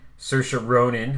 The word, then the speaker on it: Saoirse: Based on the Chekhov play with a crazy all-star cast, including Saoirse Ronan, who I've learned to pronounce her name right. – Yes, you nailed it.